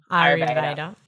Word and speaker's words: Ayurveda: Am I saying that correctly? – Ayurveda.